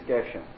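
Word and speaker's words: Geshen (גשם): In 1979, a Jew in Jerusalem published a series of articles and then a series of books arguing that the only correct pronunciation is Geshen, Mashiv Ruach Umarir HaGeshen.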